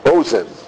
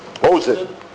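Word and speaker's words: Ozen (אוזן): You know, when we talk about, I mentioned this in Kabbalah 101, but how do you say an ear in Hebrew? – What is it? – Ozen. You were right. Ozen is an ear.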